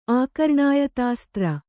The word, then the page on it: ಆಕರ್ಣಾಯತಾಸ್ತ್ರ: Kannada